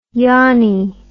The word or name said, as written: yonnie